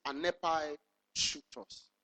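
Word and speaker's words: anepai shutos (ἀνεπαίσχυντος): Let me try and pronounce it for you and spell it for you. It's like anepai shuktos. Are you here this morning? I'll not try you on this one. I'll not test you on this one. Hallelujah. Let me spell it. A-N-E, anepai. We've been using this word, anepai, anepai. Anepai, A-N-E-P-A-I-S, C-H. It tells you it's not Ewell. This one is Greek. A-N-E-P-A-I-S, C-H-U-N-T-O-S, anepai shutos. Wow. For the last time. A-N-E-P-A-I-S-C-H-U-N-T-O-S.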